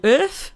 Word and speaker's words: Earth: Well, she sings in a band called Earth, which is spelled URFE.